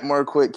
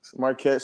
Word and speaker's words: Marquette: You can find me at Marquette King. Marquette spelled like the college, and then King spelled like King.